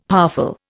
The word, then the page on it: powerful: Indian